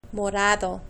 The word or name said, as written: morado